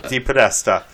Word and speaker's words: DePodesta: DePodesta.